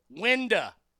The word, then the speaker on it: window: Window in the South is pronounced winda.